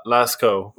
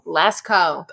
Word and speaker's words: Lascaux: Uh, but it is sp- uh, pronounced uh, Lascaux, La- Lasco. – Lasco.